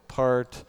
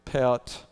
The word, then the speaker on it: Pärt: It looks to us like Arvo Part. It's probably Arvo Pärt.